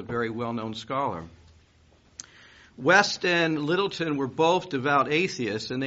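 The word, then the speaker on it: Lyttelton: One was a man named Lord George, and I believe his name is pronounced Littleton. It's got a weird spelling to it.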